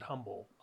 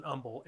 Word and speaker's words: Humble: I moved down here 20 years ago, and when I first moved down here, I pronounced it humble, obviously, because I wasn't from Texas. But now I pronounce it humble, and I pronounce the word humble, humble as well.